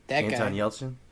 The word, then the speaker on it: Anton Yelchin: It's got Yantan Yelchnik. I don't know how to say his name. – Anton Yelchin? – Yeah, that guy.